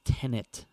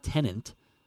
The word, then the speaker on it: Tenet: I think it's Tenet. I never know how to pronounce it. It's not Tenant. – Oh, T-E-N-E-T.